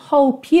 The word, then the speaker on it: hope: Aqui, hope you é uma ligação natural que você vai fazer, certo? Porque hope termina na letra E, mas essa letra E não é pronunciada, então você já vai ligar o P na próxima palavra.